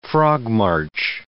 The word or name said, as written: frog-march